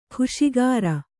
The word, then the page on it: ಖುಷಿಗಾರ: khuṣigāra